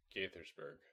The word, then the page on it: Gaithersburg: city in Maryland